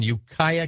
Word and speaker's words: Ukiah: Margaret's in Ukiah, California. Did I pronounce that right, Margaret? – You sure did.